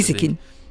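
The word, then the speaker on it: Gieseking: Giesekin. Op zijn Frans uitgesproken.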